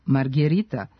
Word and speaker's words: margherita: Italijanski specijalitet, pizza margarita, zapravo se piše i izgovara sa e, kao margherita, što potvrđuje i poslednji pravopis.